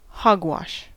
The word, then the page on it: hogwash: American English